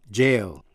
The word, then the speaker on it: gaol: Tuy nhiên, chữ G-A-O-L, đó là cách viết chính tả theo lối Anh của chữ jail, có nghĩa là nhà tù. G-A-O-L, jail. Biệt lệ, chữ get, G-E-T, G đọc là G.